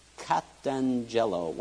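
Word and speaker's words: Katangelo (καταγγέλλω): Katangelo. When you have two G's together in the Greek, it's pronounced with an N. Take that word apart. Take the K-A-T off the front and you have angel.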